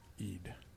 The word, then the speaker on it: Eid: It is pronounced Eid.